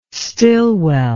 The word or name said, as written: stilwell